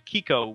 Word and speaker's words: Quico: And the game reflects that by putting you in the in control of a kid named Kiko, which is spelled Q-U-I-C-O, for the record.